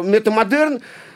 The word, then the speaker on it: метамодерн: Но метамодерн как выясняется сейчас, да, э, э, вот если постмодерн – это удар, ударение на первое, на постмодерн, да, то метамодерн, как не печально для меня, например, это ударение на второе.